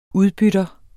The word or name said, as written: udbytter